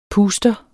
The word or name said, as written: PUSTERENS